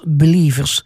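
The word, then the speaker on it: BLVRS: En dat schrijf je als B-L-V-R-S. B-L-V-R-S, en dat spreek je uit als Believers, is een christelijke pop- en EDM-duo dat bijbelse boodschappen combineert met moderne dance- en popmuziek.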